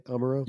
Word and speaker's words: Amuro: Did I say that right? Amuro? – Yeah.